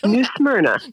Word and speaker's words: New Smyrna: How do you pronounce it? New Smyrna? – New Smyrna. – New Smyrna. Okay.